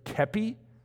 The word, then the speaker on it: kepi: Remember the French general staff would stand up in the battle with their white glove and their red hat, which I found out I was pronouncing wrong. It's, what did I say, kepi? I don't know how I said it, but it's kepi.